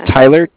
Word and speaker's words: Tyler: My name is Tyler, T-Y-L-E-R. – T-Y-L-E-R, okay. – And I'm calling with Cardiff. It's spelled C-A-R. D-I-F-F as in Frank Frank.